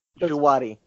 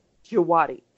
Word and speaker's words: Djawadi: I'm sure you know is does what does Wadi is that how you say it? Djawadi. Djawadi, thank you.